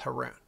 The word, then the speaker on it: Haroon: When I was in high school, everyone called me Haroon. They called me Baboon because it rhymed with Haroon. Now you can't forget it. Now you know how to pronounce my name, everybody.